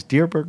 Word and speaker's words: Dierberg: Is Dierberg the right way to pronounce it? – You're correct. You got it.